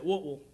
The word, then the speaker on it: Whitwell: But if you're from there, you got to say it Whitwell. You know, we know you're an outsider if you don't say it right.